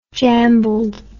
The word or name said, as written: jambled